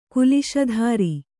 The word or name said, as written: ಕುಲಿಶಧರ